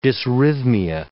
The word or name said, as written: dysrhythmia